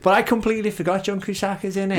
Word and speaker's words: Cusack: Cusack. No H. No H. – John Cusack.